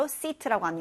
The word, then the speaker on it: seat: 영화관이나 아니면은 버스, 우리 뭐 비행기 탈 때도 굉장히 좌석이 많이 있을 때 이 좌석을 영어로 seat라고 합니다. 자, 따라해 보세요. seat seat seat. 앉다, sit는 sit, 훨씬 더 발음이 짧아요, sit. 근데 이 좌석, 자리를 의미하는 seat, seat는 seat, 이렇게 길게 발음한다는 거 잊지 마세요.